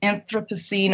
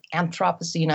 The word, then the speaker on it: Anthropocene: Right. Anthropocene or Anthropocene. I don't think that the pronunciation is stabilized yet.